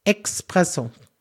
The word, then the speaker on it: Espresso: Da ist kein X drin. Espresso.